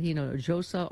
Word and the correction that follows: Hinojosa: You pronounced the J. – Don't pronounce that. – Pardon me. – You know, it's an H sound. – Pardon me. – Like San Jose.